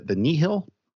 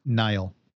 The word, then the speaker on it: Nihil: And then actually connecting to the one I just mentioned before in terms of morality, she talks about the Nihil? Is that the pronunciation? – Nile. – I thought it was Nile.